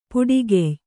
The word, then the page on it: ಪೊಟ್ಟುಗೆಯ್: kannada